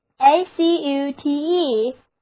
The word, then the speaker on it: acute: A C U T E， A 字母发 a，重音放在第二个音节上。单词就读作 acute，它同样表示敏锐的，是 sharp 的近义词。